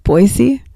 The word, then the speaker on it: Boise: Practicing saying that right, Boise.